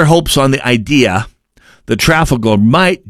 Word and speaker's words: Trafalgar: It was the Trafalgar poll, Trafalgar to most Americans. It's pronounced Trafalgar, go figure.